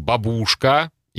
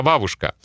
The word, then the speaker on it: бабушка: И опять-таки в этот список, ну, конечно же, попала знаменитая бабушка, которую иностранцы продолжают произносить как бабушка. И даже мои студенты, у которых уровень C1, например, они тоже иногда могут сказать «моя бабушка», я всегда говорю, не бабушка, а бабушка.